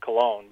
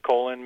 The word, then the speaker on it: Colon: I thought it was pronounced Cologne, but so it's pronounced colon, Michigan.